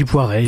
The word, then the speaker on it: Poiret: Moi, je dis Poiret. Il y en a qui disent Poiret.